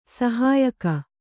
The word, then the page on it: ಸಹಾಯಕ: kannada